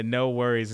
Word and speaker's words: NXWORRIES: Have y'all heard of the group Next Worries? It's no. – Anderson .Paak, and I believe it's his. No Worries. Oh, that's no. Yeah, No Worries is the Next Worries. It's okay. It's spelled NXWORRIES.